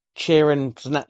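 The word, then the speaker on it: Charon: You pronounce the guy that runs the River Styx, the land of the dead, Sharon. Is that how you say it? Karen? – Sharon.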